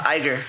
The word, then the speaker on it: Iger: No, so it is the Expo, and the Walt Disney Company chairman and CEO, Bob, how do you say his last name? I always get it wrong. – Iger.